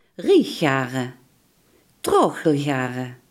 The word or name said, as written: rijggaren